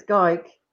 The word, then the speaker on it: Gayk: No, it's Gayk. – Gayk. I knew that. – Do you know, and I'll tell you how everyone's going to remember it, because this is what one of my son's school teachers said. It's gay with K on the end, pronounced like dyke, and you'll get it now.